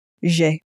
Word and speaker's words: J'ai: Make sure again, when you're pronouncing I have, to pronounce it J'ai. J'ai. – So again, it's like the English letter J, really, but pronounced in a very French way.